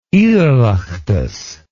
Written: oireachtas

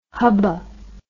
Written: hubba

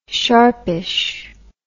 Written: sharpish

